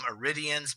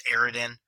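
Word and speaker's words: Eridian: Now, as anybody knows, I used to always call them Aridians, but then I realized I was pronouncing the word wrong. It's Aridan. Tanis is the one that refers to them, Aridan writing, Aridan sculptures. So, yes, I've been pronouncing that wrong for years, but it's Aridan.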